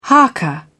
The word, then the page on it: haka: British English